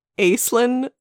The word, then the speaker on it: Aislinn: There's Ashley, A-S-H-L-E-Y, who is also Alistair. Spoilers, everyone! And there's Aislinn, A-I-S-L-I-N-N, not pronounced the way it should be Gaelic.